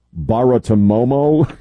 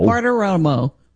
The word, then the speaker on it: Bartiromo: Baratomomo, Romo, Baratomomo. – Bartiromo.